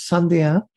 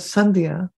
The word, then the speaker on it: Sandhya: My middle name is Sandhya. It's a Sanskrit name and it's spelled as Sandhya. When I was talking to some folks and I said, my name is Celia Sandhya Daniels, they said, what is Sandhya?